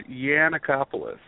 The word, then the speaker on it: Yiannakopoulos: It's Josh Yiannakopoulos. So it's like a Y instead of a G.